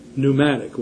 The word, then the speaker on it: pneumatic: We have that borrowed into English in the word pneumatic, where the P is silent, having to do with air.